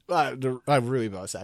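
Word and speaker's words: rooibos: I Ruibos actually is how you say that.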